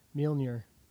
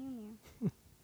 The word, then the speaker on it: Mjolnir: Mjolnir. – Yep, that's right. – There it is. – Yeah, Mjolnir.